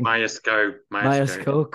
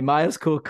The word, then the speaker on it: Myerscough: And he studied at Myerscough, if I'm pronouncing that correctly. – Myerscough College.